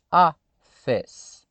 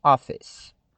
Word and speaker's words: office: E você já viu também que a palavra é dissílaba. Ela tem duas sílabas. office Simples assim, office.